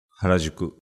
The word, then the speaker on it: Harajuku: Harajuku.